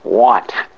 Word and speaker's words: Watt: Oh, my name is Colin Stewart Watt, W-A-T-T.